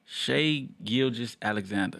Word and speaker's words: Shai Gilgeous-Alexander: Shai Gilgeous-Alexander.